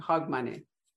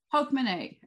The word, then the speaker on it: Hogmanay: But how do you actually pronounce H-A-U-D when you're going to say it? Is it how do you say Hogmanay? Hogmanay. – Hogmanay. It's Hogmanay. I mean, it's kind of almost phonetic, at least that's how I would say it.